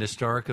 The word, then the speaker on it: historic: Well, it's a soft H, and yes, so people say an historic event.